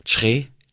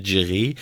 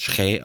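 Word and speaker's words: Kyereh: Er hat ja jetzt gesagt, wie er richtig ausgesprochen wird, nicht mehr Djere. Das hat er jetzt im Interview, im Startinterview mit Freiburg hat er gesagt, sein Name wird Schrä ausgesprochen und nicht Djere.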